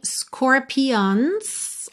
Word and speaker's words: scorpions: Aqui fica um pouquinho scorpions, ok? Mas a sílaba tônica é logo a primeira. Score, score, ok?